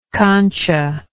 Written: cancha

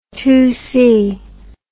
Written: two by four

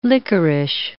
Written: lickerish

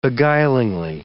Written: beguiling

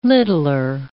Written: littler

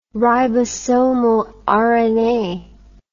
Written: ribosomal rna

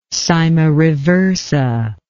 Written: cyma reversa

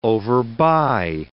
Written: overbuy

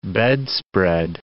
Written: bedspread

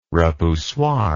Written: repoussoir

